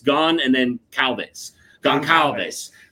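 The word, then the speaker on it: Gonçalves: Goncalves. – Goncalves.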